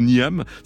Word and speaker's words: Niamh: J'ai vérifié, alors parce que ça s'écrit N-I-A-M-H, donc, mais ça se prononce pas du tout Niamh, ça se prononce, allez savoir pourquoi, Niamh.